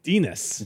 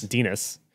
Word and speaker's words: Dines: Dines. – Dines. – Dines. – Okay, they say pronounce like penis, but with the D instead of the P.